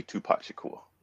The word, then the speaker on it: Tupac Shakur: You know, I'm always going back and forth on the 90s rappers, but for this purpose, it has to be Tupac Shakur.